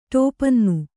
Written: ಟೋಪನ್ನು

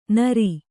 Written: ನೀರಿ

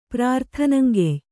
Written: ಪ್ರಾರ್ಥನಂಗೆಯ್

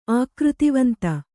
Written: ಆಕೃತಿವಂತ

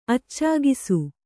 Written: ಅಚ್ಚಾಗಿಸು

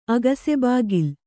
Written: ಅಗಸೆಬಾಗಿಲ್

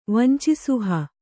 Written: ವಂಚಿಸುಹ

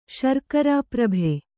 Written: ಶರ್ಕರಾಪ್ರಭೆ

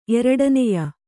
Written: ಎರಡನೆಯ

